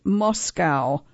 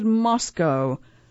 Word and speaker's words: Moscow: Outsiders pronounced it Moscow, but locals said Moscow.